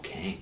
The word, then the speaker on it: Kang: Kang. – Kang. – Like the Spider-Man's clone? No, sorry. Yeah, Kang. Yeah. Didn't pronounce my G there.